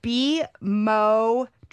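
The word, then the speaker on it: bemotrizinol: It's bimotrizenol. You emphasize the mo.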